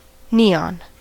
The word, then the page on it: neon: American English